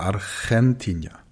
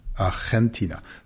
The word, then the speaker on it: Argentina: Das G wird vor einem E oder einem I auch wie ein Ch ausgesprochen. Ansonsten wie ein G. Mucho gusto, aber Argentina. Argentina.